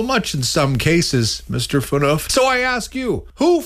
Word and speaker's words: Phaneuf: Other names included Dion Phaneuf. Rhymes with goof, according to one responder.